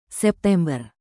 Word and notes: September: Indonesian

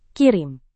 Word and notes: kirim: indonesian

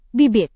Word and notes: bibit: indonesian

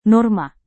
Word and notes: norma: Indonesian

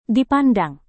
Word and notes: dipandang: indonesian

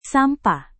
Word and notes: sampah: indonesian

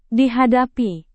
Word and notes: dihadapi: indonesian

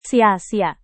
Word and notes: sia-sia: indonesian

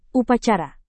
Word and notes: upacara: Indonesian

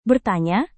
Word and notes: bertanya: indonesian

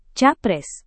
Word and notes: capres: Indonesian

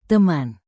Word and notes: teman: indonesian

